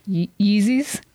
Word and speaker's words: Yeezys: So it was almost the equivalent of trying to get Yeezys. – Yep. – I said it right.